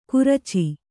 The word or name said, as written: ಗುರ್ಚಿ